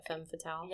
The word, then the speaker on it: femme fatale: Femme fatale? – Yeah, sounds good.